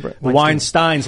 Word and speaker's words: Weinstein: Is it Weinstein? – Weinsteins, like Einstein. Those are the good guys. – Weinstein brothers by rhyming with Einstein.